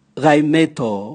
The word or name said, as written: gaimeto